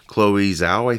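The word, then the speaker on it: Chloé Zhao: And this film's directed by, Chloe Zhao, I think that's how you say her. Say her name. Yep.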